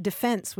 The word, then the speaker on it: defense: And you're exactly right that the traditional pronunciation is that defense with the accent on the second syllable.